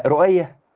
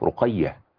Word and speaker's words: رقية: تقول له رقية، لا رؤية، لا هي رقية مش رؤية خالص، هي بالقاف رقية.